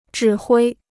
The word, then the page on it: 指挥: zhǐ huī